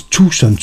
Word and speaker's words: Tucson: Udtales Tucson Train, står der.